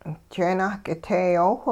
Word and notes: jeenah k'iteeyoowh: hupa

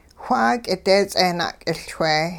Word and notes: wha k'ideets'eh na'k'iLchwe: Hupa

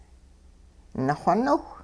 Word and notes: naxoniwh: hupa

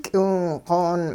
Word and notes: k'iwingq'oon: hupa

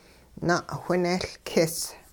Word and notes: na'whinehLkis: hupa

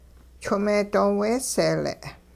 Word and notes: chwing-meedooweeseele': hupa

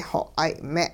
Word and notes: xoq'ay-me': hupa

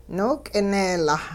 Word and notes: nook'ineelaa: Hupa

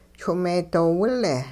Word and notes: chwing-meedoowile: hupa

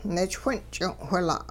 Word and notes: nichwin'ch'ing'-whila': Hupa

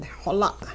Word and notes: xola': Hupa

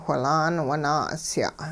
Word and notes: whilaan wun-naa'usya': hupa

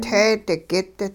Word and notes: teedigit: hupa

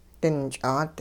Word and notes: dinch'aat: hupa

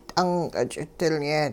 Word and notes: t'unq'-ch'idilye: Hupa

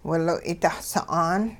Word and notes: wiloy' da'sa'aan: hupa

